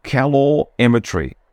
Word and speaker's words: calorimetry: Calorimetry, that's it.